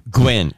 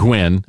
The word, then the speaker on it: Nguyen: Well, Jenny, a business owner named Jenny Gwen. It's actually pronounced Gwen. – Gwen. Okay. – Yes. – Well, it's not spelled that way. Trust me. – N-G-U-Y-E-N.